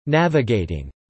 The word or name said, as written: navigating